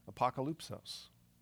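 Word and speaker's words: apokalypsos (ἀποκάλυψις): This book was written in Greek, and in Greek, Revelation is pronounced this way, apokalypsos, or as we say, apocalypse.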